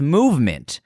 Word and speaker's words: MVMT: MVMT, pronounced movement, grew into one of the fastest growing watch brands, shipping to over 160 countries across the globe.